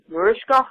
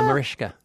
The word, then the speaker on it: Mariska: She even got the little shh on the Mariska.